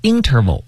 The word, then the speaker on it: interval: 下一个词叫做 interval。 – 对。当然这个词呢，我们有时也会读成 interval，就是重音在前。